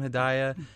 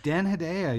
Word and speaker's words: Hedaya: It's Dan Hedaya. Is that how you say his name? Hedaya. – Dan Hedaya. Yeah.